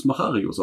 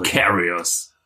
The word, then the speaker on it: Macharius: Wie würdet ihr das aussprechen? – Ich würde es Macharius aussprechen. – Macarius. – Man kann es auch Macarius sagen. – Ja. – Ich würde auch bei Macharius bleiben. – Macarius. – Kannst du auch ein bisschen Schweizerisch Macharius.